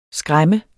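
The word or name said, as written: skræmme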